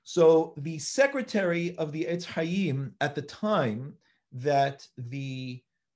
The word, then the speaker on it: Etz Chaim: And they transliterated it in English, H-E-S-A-I-M, because that was the way that they pronounced it. So now we call it the Heshaim, but that's a mispronunciation. Heshaim is Etz Chaim. That's really what it is.